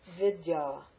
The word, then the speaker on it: vidya: Vidya. See the long ah?